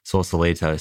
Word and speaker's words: Sausalito: And we're in a place now, correct me if I pronounce this incorrectly, is it Sausalito? – Mm-hmm. – Sausalito.